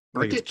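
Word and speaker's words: Brkić: Um, there's basically three kickers to look for Cade York from LSU, Gabe Bursick, I think is how you say his name, Brkic. Oklahoma. Brkic. Brkic.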